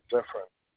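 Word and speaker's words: Zephirin: The last name, you got it right, Zephyrin. I mean, if you want me, I can spell it again. It's Z as in zebra, E Edward, P Paul, H Henry, I Island, Robert, I Island, and Zephyrin.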